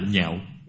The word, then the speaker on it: nhạo: văn ngã danh tự hoan hỷ tính nhạo cái chữ này đọc khác đi Không đọc là Đọc là nhạo